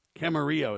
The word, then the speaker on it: Camarillo: Camarillo is how it's pronounced.